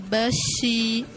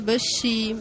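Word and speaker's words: Bashi (巴西): Por exemplo, no mandarim é Bashi por causa que eles ouvem assim, então eles escrevem desse jeito, que a pronúncia é Bashi, mas quer dizer Brasil.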